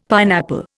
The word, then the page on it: pineapple: Indian